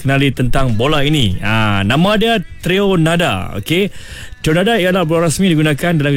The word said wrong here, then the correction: Trionda: Maksud Trionda, okay, T-R-I, okay, ejaan dia T-R-I-O-N-D-A, sebutan dia Trionda, Trionda, okay.